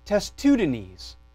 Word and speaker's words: Testudines: but a robot on YouTube told me it was pronounced testudines. And that is our 10% coupon, testudines, which is the order of reptile that our friend the turtle hangs out in.